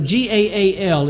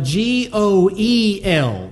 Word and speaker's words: goel: call Gaal. It's a Hebrew word. However, in commentaries and history books, it's usually spelled instead of G A A L, it's G A apostrophe A L. In history books, it's spelled G O E L, Goel, instead of Gaal, Goel.